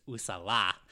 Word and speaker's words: Uzala: I tried to pay attention to how they pronounce it in the movie, and it's Usala. – Usala? – Usala.